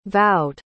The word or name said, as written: vowed